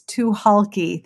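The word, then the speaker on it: Tucholke: And I was super wrong. It is too halky. The C is silent.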